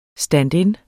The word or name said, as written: stand-in